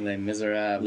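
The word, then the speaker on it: Les Misérables: Les Miserables. Look at you pronouncing it just perfectly.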